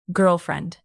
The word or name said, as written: girlfriend